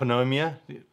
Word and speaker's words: pneumonia: Okay, so if you ever get pneumonia, you know, they don't call it pneumonia, it's pneumonia.